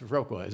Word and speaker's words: Viroqua: It's Viroqua, isn't it? I always say it wrong. – Yeah, it's Viroqua.